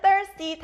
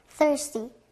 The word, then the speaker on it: thirsty: th, th 소리 나는 thirsty. 자, 그럼 목마른 thirsty 다시 발음해 볼게요. – thirsty, thirsty, thirsty.